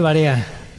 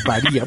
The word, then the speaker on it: varía: Varea, se dice varea. – No, no se dice varea, no digan varea, se dice varía.